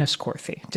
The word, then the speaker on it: Enniscorthy: in Enniscorthy. Did I say that right? – You did.